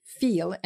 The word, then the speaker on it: Weil: W E I L, but it's a German name, so W is pronounced with a V. Veil,